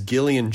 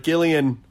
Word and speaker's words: Gillian: This episode is Gillian Jacobs, who, by the way, Will Wheaton, it pronounces the G the correct way, hard G. No shade to any soft G Jillians out there, who like my sister-in-law, who's a Jillian. But Gillian, hard G, just want to drive it home for the Wheaton. All right? Hard G Gillian, soft J Jacobs.